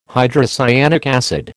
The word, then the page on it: hydrocyanic acid: American English